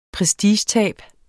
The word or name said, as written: prestigetab